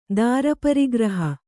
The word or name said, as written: ದಾರಪರಿಗ್ರಹ